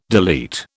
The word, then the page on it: delete: British English